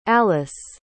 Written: allyce

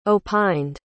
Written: opined

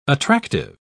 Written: attractive

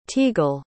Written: teagle

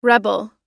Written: rebel

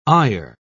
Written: eyer

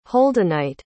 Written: holdenite

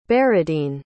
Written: beradine

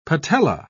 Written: patella

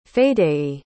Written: Fadey

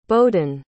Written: boden

